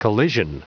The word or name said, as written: collision